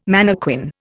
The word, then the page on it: mannequin: Indian